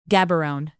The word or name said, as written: gaborone